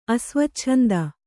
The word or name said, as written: ಅಸ್ವಚ್ಛಂದ